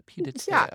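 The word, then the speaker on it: pied-à-terre: It's a pied de terre.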